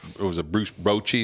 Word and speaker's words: Bochy: You know, of course, Texas brought in Bruce Bochy, is how you say his name.